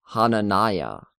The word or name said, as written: Hananiah